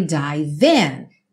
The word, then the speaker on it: dive: Watch as, juntando, I dive in. Dive, o E não é pronunciado, você já junta o V no in. Dive in.